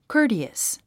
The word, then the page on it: courteous: kə́ːrtiəs; コォティアス